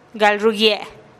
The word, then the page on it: gal rugie: TdVZ